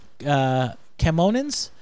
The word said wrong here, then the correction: Kaminoan: The Kamonan facility, cloning facilities during the attack. – Kaminoan. – Yeah.